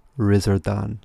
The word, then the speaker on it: Lizardon: it's spelled Lizardon, but it's pronounced Rizardon, like that.